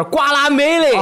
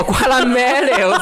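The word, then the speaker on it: Guacamelee: 古出莱塔是怎么念？ – 就是瓜拉梅雷。 – 哦，瓜拉梅雷，我操，古古出莱塔还行。 – OK，这个初代我玩过，好玩，通关了。 – 可以，瓜拉梅雷二是银河恶魔城类游戏，混合墨西哥生死世界与摔角的杰出作品。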